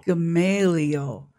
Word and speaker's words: Gamaliel: I think it is, too, and I think, you know, go back to the wise Jewish teacher, Rabbi Gamaliel, is that correct? Am I saying that correctly? – Gamaliel.